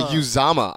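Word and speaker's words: Uzomah: Or sorry, Uzama, I believe, is the actual proper pronunciation.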